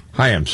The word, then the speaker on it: Chaim: It's pronounced Chaim, though, I think. – Chaim, sorry.